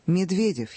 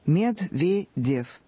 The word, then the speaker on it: Медведев: Медведев, Хиллари. Медведев.